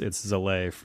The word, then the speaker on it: Zalay: I changed the spelling to make it a little bit more phonetic, but it should be Salai or something like that. But it's Zalay.